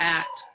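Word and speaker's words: ;at: And we have these three alien species on this planet, which are the, well, one of them is spelled with a semicolon A-T, which I'm just going to say is the at, but I think in the book they said it's pronounced like at.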